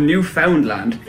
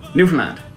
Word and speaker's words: Newfoundland: Lesson number one: It's not called Newfoundland. It's not called New Finland. It's not called New Funland. The faster you say this word, the better it sounds. Newfoundland.